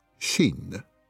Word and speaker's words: Shein: D'après mon enquête, le nom de l'entreprise se prononce comme ceci, Shane.